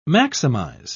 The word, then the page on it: maximise: British English